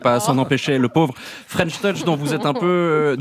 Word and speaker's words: French Touch: D'ailleurs, French Touch, c'est de l'anglais, mais ça ne se prononce pas French Touch. On dit French Touch.